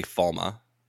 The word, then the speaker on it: Falme: It's Falme. They're calling it Falm. – I think in the books they say Falma.